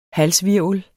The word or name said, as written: halshvirvel